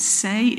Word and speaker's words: seh: The Hebrew word that is used here, I think you probably pronounce it seh, it's S-E-H, and it means the young of either a goat or a sheep.